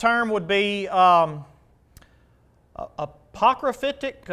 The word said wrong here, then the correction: apocalyptic: How do you say that? Apocalyptic. Thank you. There you go.